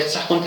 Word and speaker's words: سخن: یافته اون سخون پهلوی باشه درسته حالا چرا ما میگیم توی پهلوی توی شاهنامه باید سخن تلفظ کنیم غیر از یکی دو بیت که بین شاهنامه شناسا بحثه که مثلا با من قافیه شده ما در سراسر شاهنامه میبینیم که با واجه هایی مثل بون کن قافیه شده که شکی در اون اوه اولش نه در اون زمه خ نداریم پس یا سخن یا سخن باید اون خیر با زمین تلفظ